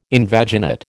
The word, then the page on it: invaginate: American English